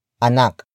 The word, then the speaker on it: anak: And that noun is anak. Anak. Anak. Anak is spelled as A-N-A-K.